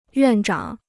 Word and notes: 院长: yuàn zhǎng

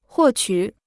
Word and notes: 获取: huò qǔ

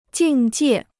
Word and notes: 境界: jìng jiè

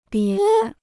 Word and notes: 别: bié

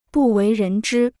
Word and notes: 不为人知: bù wéi rén zhī

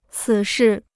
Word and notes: 此事: cǐ shì

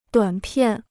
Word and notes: 短片: duǎn piàn

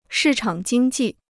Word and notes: 市场经济: shì chǎng jīng jì

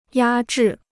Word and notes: 压制: yā zhì

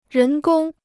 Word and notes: 人工: rén gōng

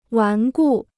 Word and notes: 顽固: wán gù

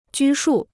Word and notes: 拘束: jū shù

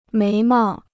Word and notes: 眉毛: méi mao

